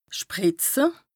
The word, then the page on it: sprìtze: Bas-Rhin; Strasbourg